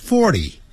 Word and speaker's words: forty: forty 念成 forty，十四呢是 fourteen，那是 four，四也是 four， four, 14，十四，四十呢 forty，它那个 u 就不见了，变成 forty，很多人会写成 forty，那是拼错的，所以念成 forty，forty，五十，fifty，fifty，六十，sixty，sixty，七十，seventy，seventy，八十，eighty，eighty，九十，ninety，ninety，不是 ninety。